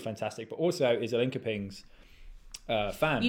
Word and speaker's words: Linköping: You know, I know how, I know how to pronounce it now. It's Linköpings. – Linköpings, excuse me. – I'm pretty sure, because I got Mia on Offside Raw Olympics podcast over the summer, and I think I heard her say it, and I was like, that's how you say it. But Mia, if you're listening, correct me if I'm wrong, but I'm pretty sure it's like Linköping.